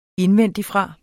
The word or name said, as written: indvendigfra